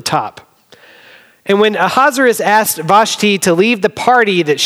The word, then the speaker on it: Vashti: Queen Vashti is how we could pronounce it, or Vashti if you're from the south.